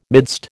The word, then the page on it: midst: American English